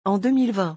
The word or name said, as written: en 2020